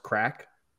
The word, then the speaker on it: craic: And in Ireland, they have a word, C-R-A-I-C, and it's pronounced crack, you know, just the same way as we pronounce our C-R-A-C-K.